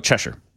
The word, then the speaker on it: Cheshire: Oh, Cheshire. That's actually how they do pronounce it.